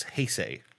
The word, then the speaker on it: Heisei: It's Heisei.